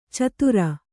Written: ಛಾದರು

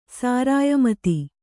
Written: ಸಾರಾಯಮತಿ

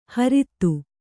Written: ಹರಿತ್ತು

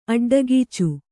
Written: ಅಡ್ಡಗೀಚು

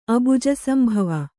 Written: ಅಬುಜಸಂಭವ

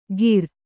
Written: ಕೀರ್

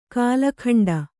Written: ಕಾಲಖಂಡ

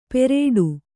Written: ಪೆರೇಡು

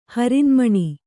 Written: ಹರಿನ್ಮಣಿ

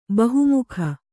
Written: ಬಹುಮುಖಿ

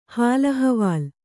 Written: ಹಾಲ್‍ಅಹವಾಲ್

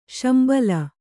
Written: ಶಂಬಲ

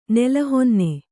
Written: ನೆಲಹೊನ್ನೆ

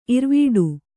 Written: ಇರ್ವಡಿ

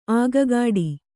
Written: ಅಗ್ಗಡ

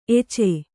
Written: ಎಚೆ

